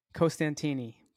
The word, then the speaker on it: Costantini: Did I say it right? – Costantini. – Boom. You heard it here. I got the lesson. I said it right the second-ish time. And now we're laughing. We're off to the races. – One too many syllables, man.